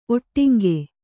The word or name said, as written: ಒಟ್ಟಿಂಗೆ